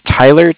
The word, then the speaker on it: Tyler: Yeah, my name is Tyler, T-Y-L-E-R, and I'm calling with Cardiff. It's spelled C-A-R-D-I-F-F, as in Frank, Frank.